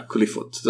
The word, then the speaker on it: Qliphoth: the Kabbalah concept of, Qliphoth. That's how it's pronounced, even though it's TH at the end.